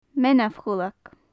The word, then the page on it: meanbh-chuileag: Gaelic